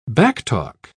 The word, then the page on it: backtalk: British English